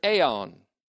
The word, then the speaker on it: aion: That is, their life in the future aeon, A-I-O-N, long O, which will be eternal.